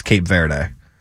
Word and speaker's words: Cape Verde: Am I saying that right? Let me see who they're actually playing. I think it's... The problem is we say things differently than the rest of the world. Yeah, it's Cape Verde.